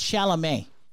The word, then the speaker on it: Chalamet: one's got Timothy Calumet. – First of all, it's Chalamet. All right? You know, like, you know, like... Pronounce it with me. Dune Chalamet.